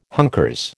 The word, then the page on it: hunkers: American English